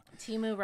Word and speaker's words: Temu: Temu rhymes with emu.